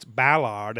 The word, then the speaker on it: Ballard: So, he pronounces Ballard as so Ballard, it's Ballard. They got to do the big hard A's in English.